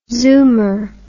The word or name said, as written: zoomer